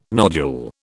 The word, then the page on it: nodule: British English